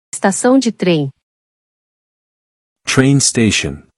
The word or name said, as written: Estacao de trem